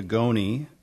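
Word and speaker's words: ngoni: Instrumentation, I believe that's a goni. It's spelled N'GONI.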